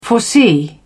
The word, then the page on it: foresee: British English